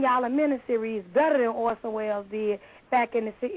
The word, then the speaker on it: Orson Welles: Orson Welles, you got it.